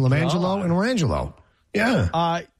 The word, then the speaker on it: Lime Jello: And she had a set of twins, and they were the names were pronounced LaMangelo and LaRangelo, but they were spelled Lime Jello and Orange Jello. L-I-M-E-J-E-L-L-O, LaMangelo.